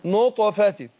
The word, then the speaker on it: نطفة: طالما انت تتعلم ربنا يبارك فيك، ناخد بالنا من ايه قلقلة الطاء. من نطق. نطفة.